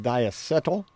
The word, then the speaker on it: diacetyl: This is the ingredient is called diacetyl. – Yeah, I think that's how it's pronounced. And for those of you playing at home, that's spelled D-I-A-C-E-T-Y-L. Diacetyl.